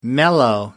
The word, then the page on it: mellow: American English